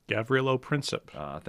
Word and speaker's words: Gavrilo Princip: Gavrilo Princip.